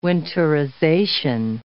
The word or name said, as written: winterization